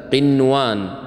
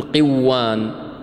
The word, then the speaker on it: قنوان: وأيضا لفظ صنوان لا تقول صوان، وكذلك قنوان ما نقول قوان، وكذلك بنيان لا تقول بيان، لأن المعنى سيختلف لأجل اختلاف المعنى، ولأن العرب عندما تنطق بهذه الكلمات لا تدغم النون الساكنة في حرف الإدغام.